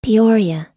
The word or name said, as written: peoria